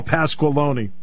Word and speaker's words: Pasqualoni: I had Paul Pacquiao, I can never pronounce his last name. – Paul Pasqualoni.